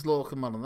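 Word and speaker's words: Lorcan Mullan: My name's Lorcan Mullen.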